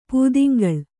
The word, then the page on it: ಪುದುಂಗೊಳ್: puduŋgol`